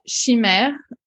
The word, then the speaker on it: chimère: How would you pronounce it as a French? – So in French, we would say chimère,